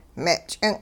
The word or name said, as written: me' ch'ing'